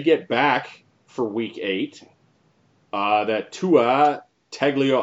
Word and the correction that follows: Tagovailoa: I got. I can never pronounce his last name. Tagovailoa. – Tagovailoa. Tua Tagovailoa.